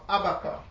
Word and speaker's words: Habakkuk: It's, you know, you'll hear Habakkuk. Some pronounce it Habakkuk, and some pronounce it Abaka. I think the American pronunciation is more Abaka.